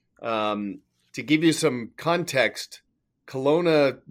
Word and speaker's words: Kelowna: Is it Kelowna? – Yeah, it is Kelowna.